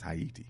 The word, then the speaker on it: Haiti: And Sanjay, S-O-N-J-E dash Haiti, A-Y-I-T-I .org. That is, of course, the correct pronunciation of Haiti, which is Haiti. You know, so just want to make sure you knew that.